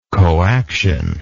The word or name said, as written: coaction